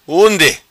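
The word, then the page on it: Undi: Macanese Patuá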